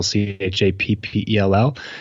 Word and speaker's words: Chappell: So I spend a lot of time on Facebook, which is, you can just look me up at Travis, last name Chappell, Chappell. Type that in the search bar and hopefully I'll come up. – All right. So I butchered your name. Then, so it's Chappell, not Chappell.